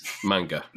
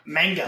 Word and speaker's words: manga: Have y'all looked at the, and I never say it right, the Mad Magma, you know, the Japanese comics. – Manga. – Manga.